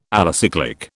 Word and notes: alicyclic: British English

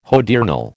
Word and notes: hodiernal: American English

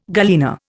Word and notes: galena: Indian

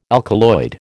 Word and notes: alkaloid: American English

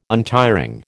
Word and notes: untiring: American English

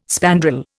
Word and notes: spandrel: Indian

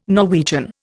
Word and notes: Norwegian: Indian

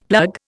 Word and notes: plug: Indian

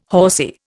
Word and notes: horsy: Indian

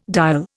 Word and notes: dial: Indian

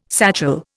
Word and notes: satchel: Indian

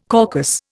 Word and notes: caucus: Indian

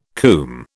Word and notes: coomb: British English

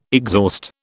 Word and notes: exhaust: British English

